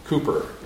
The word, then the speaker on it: Cowper: Now, if you know of his hymns, you will know that his name is spelled like you would pronounce it Cowper. But I understand it's correctly pronounced Cooper.